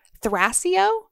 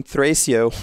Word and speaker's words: Thrasio: I'm going to horribly mispronounce it, Thrasio? Thrasio? I'm not sure how you pronounce it. Their private Thrasio business. – I was thinking Thrasio when I was reading through, but that is the power of the spoken word.